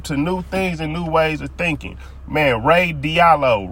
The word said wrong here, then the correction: Ray Dalio: Ray Dalio. I think I said his name right.